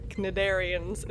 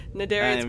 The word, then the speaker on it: Cnidarians: They really made the most of the lighting updates, because the lighting effects, I mean, on the the Farpoint Cnidarians, is that how you pronounce it? Cnidarians, probably a silent C.